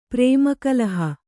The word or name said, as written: ಪ್ರೇಮಕಲಹ